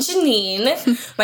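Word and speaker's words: Gennean: I'm Janine.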